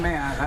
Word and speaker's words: Mann: Jonathan Mann. I've run for city council 17 times. – Spell it, please. – M-A-N-N, a man for the people.